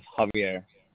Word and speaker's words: Javier: Yes, Javier. – Can you spell that for me, please? – Capital J-A-V-I-E-R.